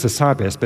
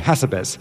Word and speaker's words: Hassabis: it's Demis Hassabis, but they say Hassabis. – Yes. – And I say, no, you have to put emphasis on the right syllable.